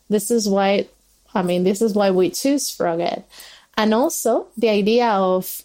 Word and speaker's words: Froged: Yeah, so the story about Froggit, in fact, we pronounce Froggit because it's Ed the Frog.